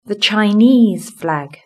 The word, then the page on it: the Chinese flag: British English